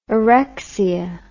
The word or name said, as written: orexia